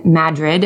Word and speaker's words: Madrid: It is Sunday, March 14th, starts at noon, goes to 6 p.m and it leaves from the Fat Tire Lounge in Madrid. And yes, that is pronounced correctly, Iowa.